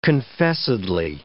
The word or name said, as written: confessedly